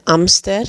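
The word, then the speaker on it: hámster: El amster. Dus je, eigenlijk schrijf je dat precies hetzelfde, hamster, maar de H spreek je niet uit.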